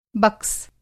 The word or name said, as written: बक्स